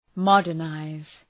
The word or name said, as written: modernize